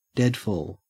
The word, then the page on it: deadfall: Australian English